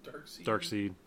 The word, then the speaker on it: Darkseid: Darkseid.